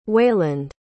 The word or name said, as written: wayland